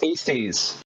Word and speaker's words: Eastes: No one knows how to pronounce it. And it's not pronounced how it's spelled. It's pronounced Easties.